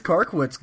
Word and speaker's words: Karkiewicz: It is Karkiewicz.